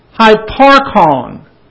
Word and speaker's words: hyparchon: The word for existing is not the usual Greek verb to be, but hyparchon. H-Y-P-A-R-C-H-O-N. Of course, in the Greek, that is spelled H-U-P-A-R-C-H-O-N, because there is no Y in Greek, and they always take a hu, hupo, and make it Y, hypo.